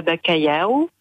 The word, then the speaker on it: bacalhau: Oui, à base de morue, je fais de la bacalhau.